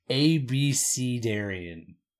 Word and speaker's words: abecedarian: Um I I am noticing here in the pronunciation guide that it is actually supposed to be ABC Darian.